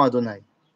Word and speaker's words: Adonai: Et quand je dis Adonai, je ne dis pas Yudke Vavke que je prononce comme étant Adonai.